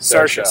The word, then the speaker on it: Saoirse: Saucery Ronan is her name. I don't want to mispronounce it. Saoirse. Sorry. I'm sorry.